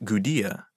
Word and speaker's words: Gudea: That light was shown by the Ensi of Lagash, a ruler named Gudea, spelled with a D, even though his name sounds quite similar to the people who had taken over Akkad.